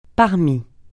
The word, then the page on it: parmi: francais